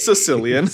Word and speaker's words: caecilian: Sicilian. Not for people from Sicily, but C-A-E-C-I-L-I-A-N. – Caecilian. – I was pronouncing the A-E like aether.